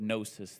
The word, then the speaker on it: gnosis: The Greek word for knowledge here is the word gnosis. The G is silent.